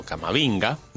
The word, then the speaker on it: Camavinga: Pocas sorpresas, a excepción de la ausencia de Eduardo Camavinga, mediocampista del Real Madrid. Pero después, a ver... – No se pronuncia así. – Camavinga, como diría un filósofo contemporáneo.